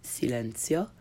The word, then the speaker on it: silenzio: silenzio, attention, attenzione, information, informazione, station, stazione, and plaza, piazza. If you struggle to pronounce the Z in these words as ts, you're not alone.